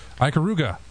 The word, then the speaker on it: Ikaruga: And in the arcade, for Xbox Live Arcade, we've got Ikaruga.